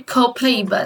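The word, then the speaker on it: copaiba: Mas em inglês a gente chama de copaíba, né? A gente faz a pronúncia diferentezinha, ó. Copaíba.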